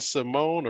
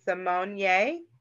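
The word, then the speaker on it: Symoné: Is it Raven Simone or Raven Simonier? Because I've seen the asterisk all this time, but she ignored it, so I ignored it. Is that what's going on with that? – She's saying that it's been mispronounced. Her name is Simonier.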